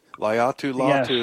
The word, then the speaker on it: Laiatu Latu: Laiatu Latu, I think is how you say his first name. – We think alike. Laiatu Latu. Yes.